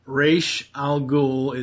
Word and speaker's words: Ra's al Ghul: The Mad Hatter, Clayface, Man-Bat, the Ventriloquist, Ra's al Ghul is how you say it, and Bane.